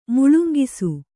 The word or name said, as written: ಮುಳುಂಕಿಸು